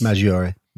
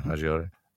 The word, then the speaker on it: Maggiore: And then after the attack, large demonstrations were held in the Piazza Maggiore. Is that how that's pronounced? – Maggiore. – Maggiore? Okay, Maggiore.